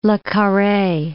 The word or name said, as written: Le Carre